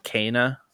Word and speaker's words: Kena: Is that actually how you pronounce it, Kena? – Yeah, like K-A-Y-N-A.